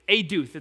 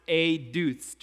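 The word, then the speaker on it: eduth (עדות): Eduth. It's spelled E-D-U-T, but pronounced eduth.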